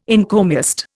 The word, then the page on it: encomiast: Indian